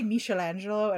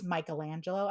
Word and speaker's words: Michelangelo: Like I'll say Michelangelo and mom's like, it's Michelangelo. I'm like, who has an art history degree here, mother? – It's the Ninja Turtle. Thank you very much. – Michelangelo is a Ninja Turtle.